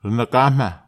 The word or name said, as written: ranakahama